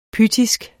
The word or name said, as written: pythisk